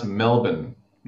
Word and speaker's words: Melbourne: Uh, and I got to say, it's pronounced Melbourne. Melbourne. How did Melbourne. I say it? Uh, M E L B N.